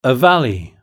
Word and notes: a valley: British English

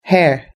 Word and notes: hair: British English

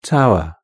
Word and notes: tower: British English

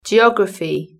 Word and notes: geography: British English

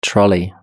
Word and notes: trolley: British English

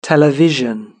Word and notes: television: British English

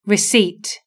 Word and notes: receipt: British English

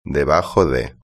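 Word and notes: debajo de: Spain

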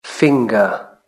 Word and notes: finger: British English

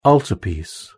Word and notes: altarpiece: British English

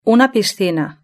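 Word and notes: una piscina: Spanish (Spain)

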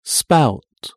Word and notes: spout: British English

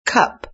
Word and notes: cup: British English

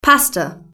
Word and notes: pasta: British English

